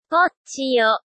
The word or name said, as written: pronunciation